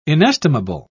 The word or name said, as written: inestimable